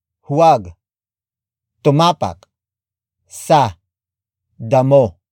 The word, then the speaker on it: damo: And that is damo. Damo. Damo. Damo is spelled as D-A-M-O.